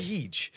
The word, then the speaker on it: Feige: Everyone says him differently. I heard he refers himself as Kevin Feige. – Okay, oh, really? – Feige. Isn't there another producer director called Feige? – I don't know.